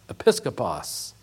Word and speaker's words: episkopos: which is pronounced episkopos, where we get our word episcopal.